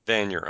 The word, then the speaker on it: Vanier: Like, you had a gentleman on there, and I may mispronounce his last name, Dean Vanier. – Yep, or is Dean it? Vanier, yep. – Vanier, okay.